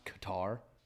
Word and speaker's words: Qatar: Noodles, did you know it's pronounced Qatar? – Yeah.